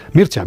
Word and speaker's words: Mircea: El rumano, ¿cómo lo pronuncias tú? Mircea. – Mircea Eliade.